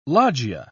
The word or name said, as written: loggia